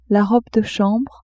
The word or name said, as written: robe de chambre